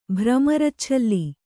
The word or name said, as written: ಭ್ರಮರಚ್ಛಲ್ಲಿ